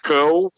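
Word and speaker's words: Kull: Outra que eu gostaria de ter, uma figura também meio rarinha, é do personagem Khal, o Conquistador, né, que a gente, quando era moleque, nos quadrinhos falava Khal. – Tá certo, né? – É o Khal, é a pronúncia correta.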